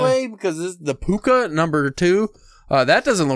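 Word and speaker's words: pooka: Uh, cause in Ireland, in uh, Gaelic, all C's are all K's pronounced, so it would be, still be Puka.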